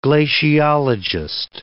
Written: glaciologist

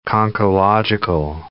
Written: conchological

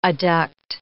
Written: adduct

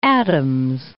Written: Adams, Mount

